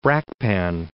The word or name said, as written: Brakpan